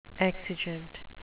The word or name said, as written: exigent